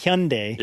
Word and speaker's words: Hyundai: Yes, we have this long-standing argument about whether it's Hyundai or Hyundai, because I speak Korean, right. – And it's pronounced Hyundai. – Yeah. – But everyone says Hyundai.